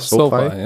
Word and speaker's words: SoFi: SoFi, ja.